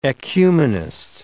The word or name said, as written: ecumenist